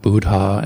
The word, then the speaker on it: Buddha: And try to pronounce all the H's, like I said, as I said before, Dhamma, Buddha, and Sangha. Try to pronounce both letters of a double consonant. That's really difficult. So Buddha or Sabba Satta.